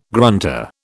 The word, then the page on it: grunter: British English